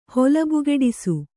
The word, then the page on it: ಹೊಲಬುಗೆಡಿಸು: kannada